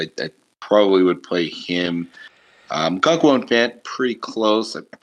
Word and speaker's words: Okonkwo: Okonow? I forget how you pronounce his name. – Okonkwo. – Okonkwo.